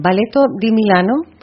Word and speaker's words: Balletto di Milano: Lo he dicho, gracias al Ballet de Milán, o a ver si lo digo bien, Balletto di Milano. – Perfecto, muchas gracias, buen día.